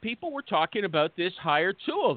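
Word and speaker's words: HireTual: For our good friend, Nin, N-I-N-H, with the unnecessary H, Nintran, okay? And he is the COO over at Hire Tool. Let me spell it for you. H-I-R-E-T-U-A-L. H-I-R-E-T-U-A-L.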